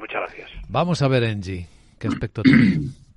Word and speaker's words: ENGIE: Sí, en o ENGIE, como se pronuncia. Sí, en, en castellano literal, ENGIE, sí.